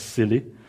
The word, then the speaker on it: SIYLI: It's so new that when my organization, the Search Inside Yourself Leadership Institute, SIYLI, pronounced as SILI, so SILI wanted to run a course on joy, and at first I said no, but eventually I said yes.